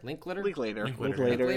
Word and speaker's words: Linklater: It was written, directed by, I never know how to pronounce this guy's name, Richard Linklater? – Linklater. Is how I've always said it, yeah.